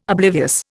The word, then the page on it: oblivious: Indian